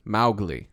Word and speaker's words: Mowgli: Mowgli, spelled M-O-W, was supposed to be pronounced like cow. – Mowgli? – Mowgli.